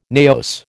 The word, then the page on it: naos: American English